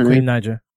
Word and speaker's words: Queen Naija: Let's go on to number 10, Thank God I Made It, featuring Queen Naija. Is that how you pronounce it? – Yeah, Queen Naija.